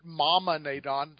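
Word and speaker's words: Momaw Nadon: he became Momon Nadon. – Well, and if you pronounce it Mama Nadon, that would be okay, because it was never pronounced on screen.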